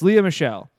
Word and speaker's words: Lea Michele: Yeah, it's Lea Michele, star of Glee.